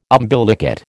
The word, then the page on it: umbilicate: American English